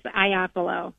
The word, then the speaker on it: Ioppolo: Well, you were close. It's Ioppolo. – Ioppolo. Okay, wrong emphasis Ioppolo. On the wrong syllable, but thank you.